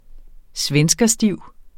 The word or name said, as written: svenskerstiv